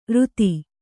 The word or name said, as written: ಋತಿ